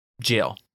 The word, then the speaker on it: gaol: So how do you pronounce, and maybe you already know this, but how do you pronounce this word? G-A-O-L. – Jail. – Jail, yeah. – It is jail, but how would you pronounce it if you didn't know it was pronounced gaol.